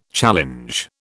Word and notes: challenge: British English

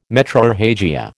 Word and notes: metrorrhagia: American English

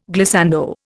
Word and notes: glissando: Indian

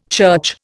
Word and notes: church: Indian

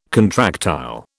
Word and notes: contractile: British English

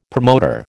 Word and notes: promoter: American English

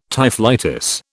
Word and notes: typhlitis: British English

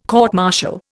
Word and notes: court martial: Indian

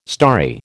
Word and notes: starry: American English

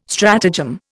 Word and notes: stratagem: Indian

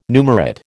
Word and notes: numerate: American English

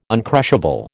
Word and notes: uncrushable: American English

